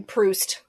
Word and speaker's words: Proust: Proust, if you want to use the English pronunciation.